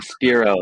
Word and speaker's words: Spiro: Greg Spiro, like spear.